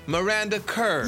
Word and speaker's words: Miranda Kerr: Miranda Kerr.